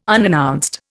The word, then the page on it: unannounced: Indian